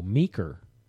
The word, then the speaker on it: Meeker: Oh, Meeker. Oh, okay. I thought you were mispronouncing Mika, like M-I-C-A. No, that's, for those of you out in radio land, he's saying Meeker, M-E-E-K-E-R, which is a fine town on the western slope of Colorado.